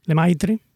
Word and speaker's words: Lemaitre: de Pierre Lemaitre. Lemaitre, ¿lo he dicho bien? ¿Mm? Lemaitre, en francés.